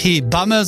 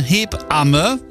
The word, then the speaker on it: Hebamme: Und ja, wir müssen uns beim Aussprechen von Hebamme richtig Zeit lassen in der Mitte. Das heißt ja nicht Hebamme, sondern Hebamme.